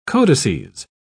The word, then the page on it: codices: British English